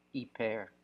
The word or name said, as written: iper